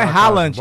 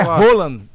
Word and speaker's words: Haaland: Não é Haaland, é Roland. É Roland? É assim que pronuncia.